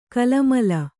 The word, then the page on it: ಕಲಮಲ: kalamala